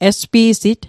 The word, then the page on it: espi'sit: Mi'gmaq